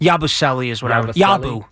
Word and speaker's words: Yabusele: That's what I said. It's Yabusele. I'm telling you. It's Yabusele.